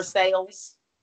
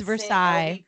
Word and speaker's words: Versailles: The Versailles. – That one, that sent me as well. I was like, oh, is that just how y'all pronounce it in Kentucky? I was just like, enough. It's Versailles.